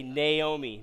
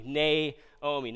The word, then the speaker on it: Naomi: I mispronounced her name last Sunday, and I know some of you maybe lost sleep over that, but I repent, and I'm going to say Naomi. Naomi. Not Naomi, Naomi.